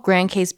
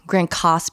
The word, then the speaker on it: Grand Case: The city that we stay by is called Grand Cas, but it's spelled like case, and they do correct you on that one.